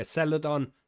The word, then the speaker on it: Celadon: To try and provide more robust evidence on the use of the drug, Caledon... I'm going to spell it for you because I'm butchering however that's spelled. It's Celadon. Celadon. Yeah, mate. Yeah, Celadon Pharmaceuticals is now starting its first clinical trial of its kind in 5,000 patients with chronic pain.